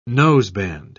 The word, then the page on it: noseband: British English